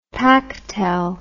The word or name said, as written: pactel